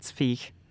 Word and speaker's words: Zweig: Called Beware of Pity by Stefan, I'm going to pronounce this the German way, Zweig.